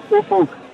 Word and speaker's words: Ufuk: How do we say his name correctly? – It's Ulfunk, but we all call him Ulfi to sort of save the embarrassment. Ulfie. – Has anyone ever said the magic word? Because it's spelt U-F-U-K.